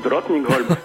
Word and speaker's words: Drottningholm: Drottningholm, il y a beaucoup plus compliqué.